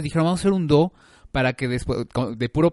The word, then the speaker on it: dhow: que se llama do Se escribe D-H-O-W,